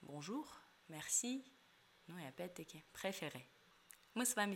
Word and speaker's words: préféré: Посмотрите, например, на слово «préféré», как здесь произносится мягко «р». Если мы сравним его с неправильным произношением «préféré», вот «р» не должно быть ни в коем случае, да, это, и не должно быть никакого там то же самое «préféré», вот не надо, просто «préféré», мягкий, легкий,